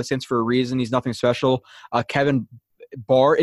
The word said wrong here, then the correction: Byard: is it Bard or Byard? I forget. I don't know how to freaking pronounce his name. – I thought it was Byard.